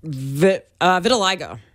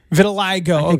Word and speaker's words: Vitiligo: Vitiligo. – Vitiligo.